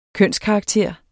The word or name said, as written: kønskarakter